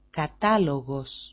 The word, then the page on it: katalo6os: kata-lo6os